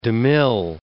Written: DeMille

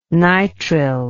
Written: nytril

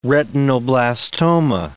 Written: retinoblastoma

